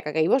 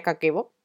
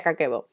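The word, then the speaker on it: Kakeibo (家計簿): El Kakeibo como tal es un diario de ahorro japonés, que por cierto no se pronuncia Kakeibo, se pronuncia Kakeibo, ¿vale? Por si os ponéis a buscarlo, se pronuncia Kakeibo.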